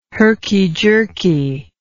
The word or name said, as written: herky jerky